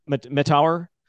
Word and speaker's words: Mettauer: Mettauer.